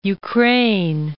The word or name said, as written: Ukraine